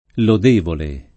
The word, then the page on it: lodevole: italiana